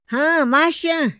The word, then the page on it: haa maxa: Otomí